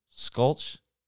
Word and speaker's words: Scultch: How would you spell that? Is it... – S-C-U-L-T-H. Like sclutch. But it's got to be sculch. – Sculch? T-C-H? – S-C-U-L-T-C-H, yeah. Okay. Sculch. Sculch, it's a place where you can eat burgers that were just killed.